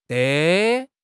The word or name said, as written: de5